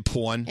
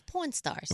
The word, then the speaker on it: Pawn Stars: You say porn. – It's Pawn Stars.